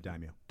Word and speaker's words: daimyo: Instead, they hand it off to, um, I think it's pronounced daimyo. – Yeah, the daimyo. – Daimyo. So these are regional leaders that they're friendly with.